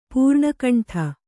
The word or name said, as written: ಪೂರ್ಣಕಂಠ